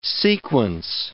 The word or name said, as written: sequence